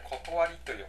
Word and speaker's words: 理: これ音読みだと「り」ですけど、訓読みだと、なんて読むかお分かりでしょうか。これ「断り」と読むんですけれども、例えばこれですと、物の断りって書いて、物理って読むと思うんですけど、読むんですけども、物の断り、例えば物が上から下に落ちるとかですね、物には断りがありますよね。